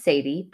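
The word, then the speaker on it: Sattie: My name is Sadie, pronounced Say-T, Say-T.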